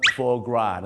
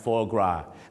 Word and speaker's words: foie gras: อย่าลืมครับเขาออกเสียงว่า foie gras นะฮะ foie gras ไม่ใช่ฝอยกราสนะครับ